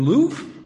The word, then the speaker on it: Louvre: You're in the Louvre? You don't pronounce the R.